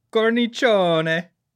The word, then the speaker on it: cornicione: It's cornicione.